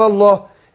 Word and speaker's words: الله: في اللام الأولية بالرقاقة ورحت داخلين على تفخيم لام لفظ الجلال، فانطق ازاي؟ يقول ايه؟ وما أنزل الله، وما أنزل الله، ما ينفعش اقول وما أنزل الله لا الله لا لا الله.